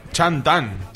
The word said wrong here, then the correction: Chang'an: Chang'an. – Sí, Y el nombre Chang'an directamente.